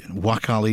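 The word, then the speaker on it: Nwakali: But we've got Michael Devlin in, Sam Cosgrove, Niles back, Chidi Nkwali has come in. Sorry, Wakali, you've got to drop indeed the N when you pronounce it.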